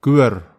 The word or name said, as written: kywer